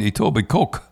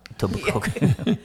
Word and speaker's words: Etobicoke: I remember when I first came here, I used to call it Etobicoke. – Etobicoke.